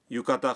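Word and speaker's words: یوکاتا (浴衣): اگر آپ کسی کے لیے تحفے کے طور پر گرمیوں کا ہلکا کیمونو یوکاتا خریدنا چاہتے ہیں تو یہ کس طرح کہیں گے.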